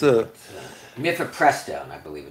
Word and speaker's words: mifepristone: It's not Minestrone. That's Mifepristone, I believe it's. – Mifepristone.